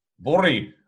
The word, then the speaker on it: Voirrey: So in the 30s, the Irving family, James, the father, Margaret, and a 13-year-old daughter called Vire. How do you pronounce this? V-O-I-R-R-E-Y. – What? – V-O-I-R-R-E-Y. – Vori. – Right, okay, so I was close enough.